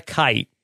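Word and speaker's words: Khait: How would you pronounce that word? – I would say kite.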